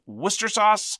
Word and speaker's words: Worcestershire: Spelt L-E-I-C-E-S-T-E-R, very much like Worcestershire sauce, which is pronounced Worcester sauce.